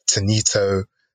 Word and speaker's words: Tani: I've gotten Tanito, I've gotten Tanit. So yeah, so I always have to be like, when they start pronouncing my name, I just have to say, yes, Tani.